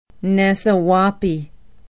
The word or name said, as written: nasewaupee